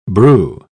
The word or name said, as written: brew